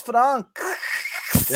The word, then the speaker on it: Franxx: You know, it's spelled FRANXX. – That's why it's Fronks.